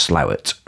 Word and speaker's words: Slaithwaite: The idea was we'll walk from Marsden to Slowett, as it's pronounced. It's written Slaithwaite, but it's pronounced Slowett, right? – Right.